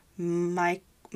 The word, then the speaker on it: Michaelides: Hopefully, you know, I'm not good with pronunciation sometimes, but it's just Michael and then Ides, like Ides. So Michael Ides.